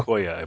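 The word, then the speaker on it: Giacoia: Giacola, I believe. – Giacola. Oh, yeah, there's no L.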